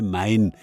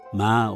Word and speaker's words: Main: Aus dem lateinisch überlieferten Moinus oder Mönus entwickelte sich der Schreibname Main, der allerdings von seinen Anrainern mit typisch nasaliertem N ausgesprochen wird, ma oder ma, me oder mä.